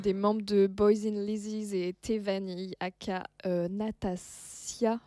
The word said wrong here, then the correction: Nastasia: Je pense que ça se prononce. – Nastasia. Nastasia, pardon, excuse-moi.